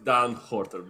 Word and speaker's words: Dan Horter: Unfor- If you want to go all Dutch, it's Dan Horter, but it's all good.